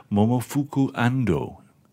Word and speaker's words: Momofuku Ando: 1958, that was, invented by Momofuku Ando.